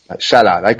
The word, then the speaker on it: Salah: The Egyptian, as he's pronounced, Salah.